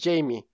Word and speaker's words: Jamie: Y están Fetch, The Bold Chorus, de Fiona Apple, Hyperspace de Beck, Punisher de Few Bridges, Jaime de Bethany Howard y The Slow Rush de Taming Pal Creo que es Jamie. La pronunciación, no Jaime.